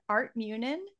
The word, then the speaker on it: Art Munin: So joining me are Art Munin.